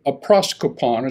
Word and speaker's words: aproskopon: Without offense is the Greek word aproskopon. It's spelled A-P-R-O-S-K-O-P-O-N.